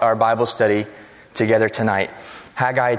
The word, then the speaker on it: Haggai: And Haggai, you could say it a couple of different ways. Haggai, Haggai. I say Haggai. I think that's the proper way to say it. Just basically say Haggai with a southern twang. Haggai. That's how you say it.